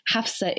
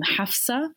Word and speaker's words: Hafsah: In fact, Hafsah isn't really even how you would pronounce my name. It's an Arabic name, and the ha isn't even ha, it's like ha. So you would actually pronounce my name Hafsah in Arabic if you were really being pedantic about it.